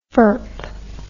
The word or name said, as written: furp